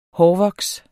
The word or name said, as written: hårvoks